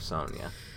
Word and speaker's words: Sonia: Like, Sonia, I would approve of Sonia. – Over here in Britain, we pronounce it Sonia. – Sonia Blade. – Like, I've seen ya, I've Sonia.